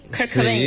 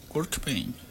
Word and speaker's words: Kurt Cobain: Porque Kurt Cobain, ¿lo digo bien? – Kurt Cobain. Sí, está. Kurt Cobain.